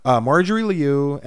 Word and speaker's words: Marjorie Liu: No, Marjorie Liu and Sana Takeda. – Good job. – They're the writer and artist for Monstrous. Monstrous is so good. So Marjorie Liu won.